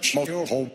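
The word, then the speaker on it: Schmoyoho: And I want to thank Shmoyoho. Shmoyoho, accent on the yo.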